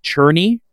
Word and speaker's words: Czerny: Upon doing a little bit more research, it is pronounced Cherny like you are churning butter.